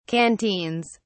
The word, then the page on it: canteens: British English